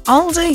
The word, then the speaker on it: Aldi: Aldi. – It's Aldi. – You're saying Owl. – No, it's Aldi. – Aldi. – As in A-L. But Aldi.